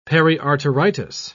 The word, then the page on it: periarteritis: British English